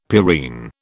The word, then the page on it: purine: British English